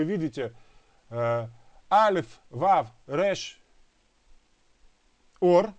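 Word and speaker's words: Ор (אור): Точно так же звучит слово еще одно. Оно читается как ор, но переводится оно как кожа. Заметьте, два слова читаются как ор.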